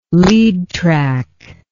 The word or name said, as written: lead track